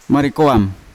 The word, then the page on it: marikoam: Yawa